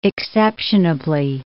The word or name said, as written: exceptionably